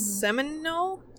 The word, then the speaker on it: Seminole: And before it was farms, it belonged to the Simoli Indians. – Simoli? Isn't it like mm-hmm. Seminole? – Seminole? I got into my own head about saying it right because I can't spell it right. – And it's S-E-M-I-N-O-L-E, correct? – Yeah, Seminole?